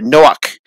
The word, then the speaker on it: Noach: You know, Noah has a C in there, Noach.